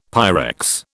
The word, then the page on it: Pyrex: British English